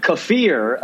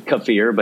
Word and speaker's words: kefir: And also, before we go, I have to apologize for the we were pronouncing kefir apparently totally wrong. – You are such an asshole. – Kefir. it's delicious is kefir, but it's not kefir, I guess. I like kefir better, but kefir. Thank you for correcting us on that.